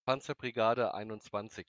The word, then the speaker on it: Panzerbrigade: Can you guys tell us just pronounce that in German again? – Panzerbrigade 21.